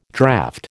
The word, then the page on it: draught: American English